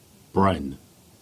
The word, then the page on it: Brynn: Cornish